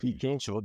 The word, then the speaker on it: Fico: Pijo, er det riktig uttalt? Pijo, det er riktig uttalt.